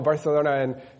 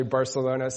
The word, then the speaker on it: Barcelona: Barcelona," and I said, "No, it's actually Barcelona." So I will give you guys the inside insider information that the actual pronunciation is Barcelona.